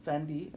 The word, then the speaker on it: Fendi: That's Fendi, not Fenty.